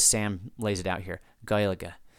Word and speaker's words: Gaeilge: Number one, in Ireland, they refer to the language as Irish or Gaeilge, which is pronounced like, and then he spells it out, Gaeilge.